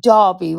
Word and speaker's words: Derby: It's Derby, we say. As it's spelled D-E-R-B-Y, but we pronounce it D-A-R-B-Y, so Derby.